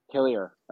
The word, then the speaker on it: Hillier: And what is the pronunciation of your last name? – Hillier. – Hillier. Okay. – And kind of just rolls off the tongue.